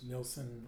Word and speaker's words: Nilsson: So I would just, my LinkedIn profile, my name is Lars Nilsson, last name is spelled N-I-L-S-S-O-N.